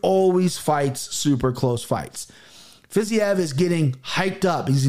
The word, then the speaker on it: Fiziev: Fiziev.